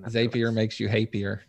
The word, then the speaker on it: Zapier: If everybody doesn't know if it's Zapier or Zapier, Zapier rhymes with happier is what they said.